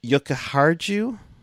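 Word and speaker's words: Jokiharju: Yokoharu. Silent J, my bad.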